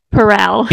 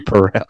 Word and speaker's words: Perel: And we're 99% sure that you pronounce it Perel. – You can put that in a blooper reel if you want, but I'm telling you, it's Esther Perel.